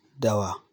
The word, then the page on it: Dawa: Hausa